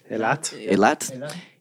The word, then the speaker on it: Eilat: Earlier this year, in response to Tel Aviv anointing itself the dog-friendliest city, a group of 12 rabbis from Eilat, am I saying that right? – Eilat.